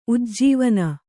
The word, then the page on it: ಉಜ್ಜೀವನ: ujjīvana